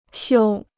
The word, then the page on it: 修: xiū